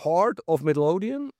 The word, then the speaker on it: Heart of Midlothian: Wir haben Heart of Midlothian, habe ich echt das richtig ausgesprochen.